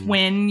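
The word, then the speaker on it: Nguyen: Yeah, I was just going off of the like the Vietnamese, like, you know, if somebody has that N-G-U-Y-E, it's like it's win. – It's win usually.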